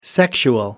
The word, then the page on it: sexual: British English